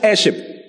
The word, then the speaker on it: heirship: Heirship is spelled H-E-I-R-S-H-I-P. Not hair, so not hair. Heir. H-E-I-R-S-H-I-P. Joint heirship. Heirship. Okay?